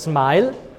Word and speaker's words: SMIL: Das eine heißt SMIL oder genau genommen heißt SMILE.